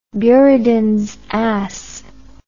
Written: buridans ass

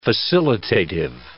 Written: facilitative

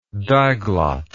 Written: diglottic

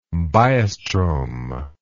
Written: biostrome

